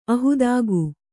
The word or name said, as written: ಅಹೇತುಕ